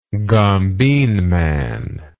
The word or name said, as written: gombeen man